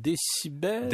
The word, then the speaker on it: DCBEL: en fait, euh, voyez ça comme décibel. – D-C-B-E-L, mais ça se prononce décibel, donc c'est quelque chose comme ça.